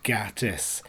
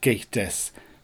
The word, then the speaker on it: Gatiss: Mark Gatiss was on top form, and when they came to ask in the audience questions, somebody in the audience mispronounced his name as Mark Gatiss, to which he turned away, said, "It's pronounced Gatiss," put his watch to his mouth and said, "Kill her," and completely walked away and ignored her.